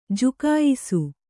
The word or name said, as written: ಜುಕಾಯಿಸು